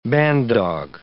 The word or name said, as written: bandog